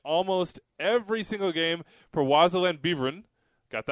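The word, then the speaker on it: Waasland-Beveren: So then he gave us the pronunciation on his club, which is, as I said, Wazeland Beveren. So, yeah.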